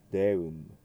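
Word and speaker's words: Deum: クレード・イン・ウヌム・デウム。今の部分で語尾が M であることにお気をつけください。日本人なんとなくこういうところではウーヌンというふうに N の発音になりやすいです。ウーヌンではなくてウーヌン、デウンではなくてデウン、そのように注意してください。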